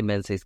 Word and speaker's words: Menzies: I feel like that should be pronounced Ming, but, you know, like Ming Campbell. But anyway, I'm going to say Menzies because I think that's how he pronounces it. With apologies, as ever, to Scotland.